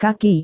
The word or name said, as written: kaki